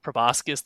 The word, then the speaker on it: proboscis: like a mosquito-type proboscis. That's not how you pronounce that. Mosquito-type like needle, proboscis. There we go.